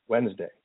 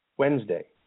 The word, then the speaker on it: Wednesday: Wednesday. Can you hear the D? – Not at all. – No, not whatsoever, right? Let me say it again. Wednesday.